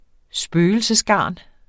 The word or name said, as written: spøgelsesgarn